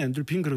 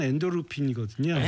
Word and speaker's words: 엔도르핀: 발음은 보통 우리가 엔돌핀, 엔돌핀 그러는데요. 네. 예. 의학적은 엔도르핀이거든요. – 엔도르핀.